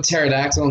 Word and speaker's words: pterodactyl: I mean, you look up pterodactyl and the fucking dish. Why can't you hear a pterodactyl go to the bathroom? Because the P is silent.